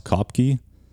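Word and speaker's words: Koepcke: I'm not sure how to pronounce her name. It's pronounced Kopke, who was 17 years old at the time, fell thousands of feet, still strapped into her seat.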